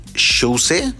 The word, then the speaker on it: Shouse: Eh, es original de Shouse, no sé cómo se pronuncia, pero se escribe S-H-O-U-S-E. Como si escribieras House, pero le pones una S al principio, Shouse.